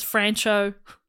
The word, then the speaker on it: Franchot: Can we all agree it is, just for listeners, it is Franchot, but I'm going to keep calling him Franchot.